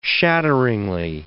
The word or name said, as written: shatteringly